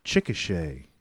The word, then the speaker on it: Chickasha: You're from Chickasha. – You pronounced that correctly.